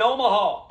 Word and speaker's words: Omaha: We might uh, we might need you in Omaha. Coach, one more time. It's kind of three syllable. It's rhythmic, right from the diaphragm.